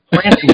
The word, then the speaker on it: Plantinga: And she called me up and she said, Adam, you're not pronouncing your own name right. It's Plantinga. You have to pronounce the T.